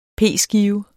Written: p-skive

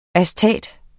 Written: astat

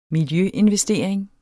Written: miljøinvestering

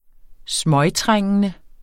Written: smøgtrængende

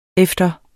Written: efter

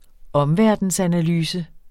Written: omverdensanalyse

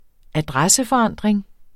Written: adresseforandring

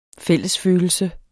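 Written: FÆLLESFØLELSEN

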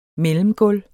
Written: mellemgulv

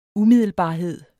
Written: umiddelbarhed

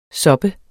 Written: soppes

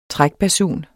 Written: trækbasun

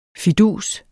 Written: fidus

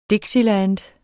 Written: dixieland

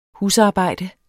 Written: husarbejde

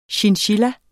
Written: chinchilla

